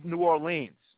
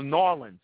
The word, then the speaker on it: New Orleans: New Orleans, or Norlands, as they say, the locals say.